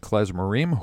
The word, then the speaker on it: Klezmorim: You're listening to Drew Howard and David Klein talking about their band, Heartland Klezmorim, who, I beg your pardon. Thank you very much. Accent on the right syllable.